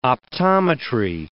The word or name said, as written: optometry